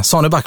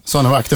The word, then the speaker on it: Saneback: För det, det, det har varit lite en diskussion här, Sara Wittbom och Arne Andersson, huruvida mitt efternamn ska uttalas. Men eh, Saneback blir det. Det blir bra. Saneback, det funkar.